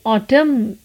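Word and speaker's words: autumn: ऑटम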